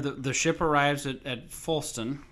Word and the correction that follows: Floston: Okay, I have to make sure I'm saying that right. Folston, and everybody's Floston. – Floston, sorry.